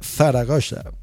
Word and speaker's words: Zaragoza: Bueno, ya me regañaron, me dijeron que se pronuncia Zaragoza.